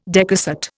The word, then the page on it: decussate: Indian